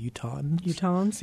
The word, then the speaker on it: Utahns: Richard Bug, who is the artistic director and the founder of the festival, he's really done a great thing for Utah and Utahns. Is that how you say it? Utahns, yeah, just an N on there.